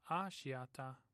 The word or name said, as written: ashiata